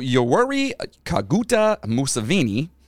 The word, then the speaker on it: Yoweri Kaguta Museveni: So, Yoweri Kaguta Museveni rhymes with a another person that's not very nice. – Mussolini? – Yeah.